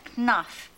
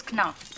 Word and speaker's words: Knopf: I was an editor, corner office at Knopf. It is Knopf, by the way. Say the K, not the P.